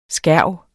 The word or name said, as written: skærv